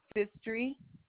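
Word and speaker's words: Phistry: Sherry Fistry.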